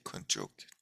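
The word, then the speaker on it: Könchog: The G is pronounced like a K, Kunjok Jigme Wangpo.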